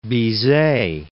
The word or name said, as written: Bizet